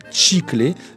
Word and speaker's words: chiclé: Compte tenu de l'existence en français standard du mot chiclé, qui s'écrit chiclé mais qu'on prononce, si on garde l'accent espagnol d'où ce mot est tiré, chiclé, c'est un mot qui désigne le latex qui découle notamment du sapotillier et qui est utilisé dans la préparation des chewing-gums.